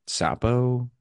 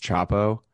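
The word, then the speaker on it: Csapo: Sapo? C S A P O. I think it's pronounced Chapo. Um mm, mm-hmm.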